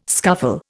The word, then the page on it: scuffle: Indian